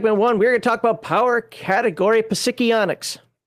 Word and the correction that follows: psionics: That's how you pronounce that, right? – Sure. No, not at all. Oh, okay. Go ahead and throw up the PDF for psionics.